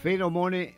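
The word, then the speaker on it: Pheromone: Feromone Maxim, porque es PH, se pronunciaría una F, ¿no? Si no me equivoco.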